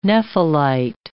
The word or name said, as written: nephelite